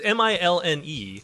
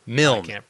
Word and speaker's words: Milne: It's M I L N E. – Here, I wrote it down. – Well, here I got this audio thing. – Milne.